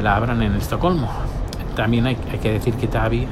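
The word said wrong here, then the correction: Täby: Tevi se escribe TA con, con dos puntitos arriba,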